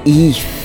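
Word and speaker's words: Aoife: That was Eif. I hope I pronounced it right. It's A-O-I-F-E, O'Donovan and Passengers.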